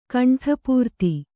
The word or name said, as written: ಕಂಠಪೂರ್ತಿ